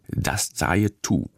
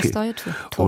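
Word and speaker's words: Dast-e To: Das Zaye Tu. Ja. Ist richtig ausgesprochen. – Ja, okay. Das Zaye Tu.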